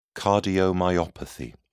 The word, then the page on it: cardiomyopathy: British English